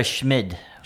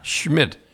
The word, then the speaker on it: Schmid: Akira Schmid. – Schmid.